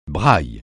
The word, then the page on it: braille: francais